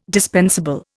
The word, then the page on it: dispensable: Indian